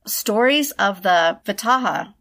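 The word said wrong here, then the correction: Vetala: No. – I must just say I'm enjoying the struggle with pronunciations. – I know, because it's usually you. – It's usually me on the other side. – It looks like it's Vitala. So,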